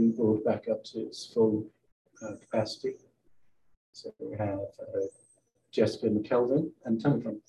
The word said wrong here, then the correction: McElvain: Pronouncing that right or wrong? – McElvain. – McElvain. – Yes. – Thank you. – With the Irish spelling.